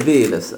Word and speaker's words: Vives: Oh, so Carlos Vives. Is that how you say his name? – Carlos Vives. – Vives, okay.